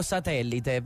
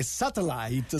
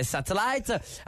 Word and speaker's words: satellite: Allora, io leggo satellite, però tu dicevi che... – Satellite. Dovrebbe essere questa la pronuncia. – Dovrebbe essere questa, bene, noi viviamo di dovrebbe, forse, no, qualora, insomma... Satellite. No, è satellite.